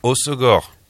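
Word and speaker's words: Hossegor: Et le 2 août à Osgore.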